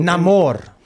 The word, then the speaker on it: Namor: Is that how it's pronounced now? – Uh-huh. – Okay, Namor. Well, not for my entire life of reading Marvel comics, but whatever. – I don't think I'm supposed to roll the R, but...